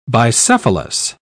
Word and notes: bicephalous: British English

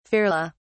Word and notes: ferly: British English

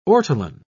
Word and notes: ortolan: British English

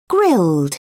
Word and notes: grilled: British English

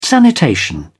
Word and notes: sanitation: British English

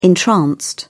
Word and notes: entranced: British English

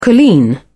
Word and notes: colleen: British English

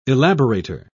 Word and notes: elaborator: British English

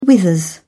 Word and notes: withers: British English

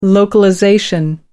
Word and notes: localization: American English

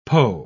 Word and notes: poe: British English

